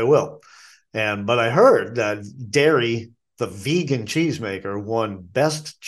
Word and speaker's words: Darë: Dairy is a vegan cheese company. It's spelled D-A-R-E, and there's an umlaut above the E, and it's pronounced dairy.